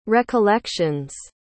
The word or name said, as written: recollection's